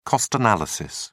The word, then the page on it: cost analysis: British English